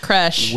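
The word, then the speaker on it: crèche: Anyway, the woman expected a crochet to be set up at the wedding. – It's just pronounced crash.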